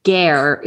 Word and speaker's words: Guerre: I think it's pronounced Gare. It's okay. G-U-E-R-R-E.